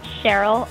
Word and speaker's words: Sheril: Well, you can find me on Twitter at Cheryl underscore, but it's spelled S-H-E-R-I-L, and then that little underscore after my name.